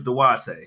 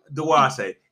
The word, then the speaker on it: Dwate: Okay, my name is Duwate. You wanna spell it D-W-A-T-E? I'm the only one out there. Do what you do. Duwate.